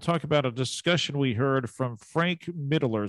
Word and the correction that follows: Mittlerner: Is that how you pronounce his last name? – Mittlerner. – What is it? Yep. Mittlerner? – Frank Mittlerner. Dr. Okay.